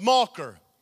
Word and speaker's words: Machir: In Hebrew, means mocker. Pronounced mocker.